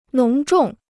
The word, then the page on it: 浓重: nóng zhòng